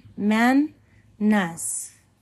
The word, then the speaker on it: Mannaz: The next one is Mannaz, Phonetic value is M. Pronunciation, man, ness.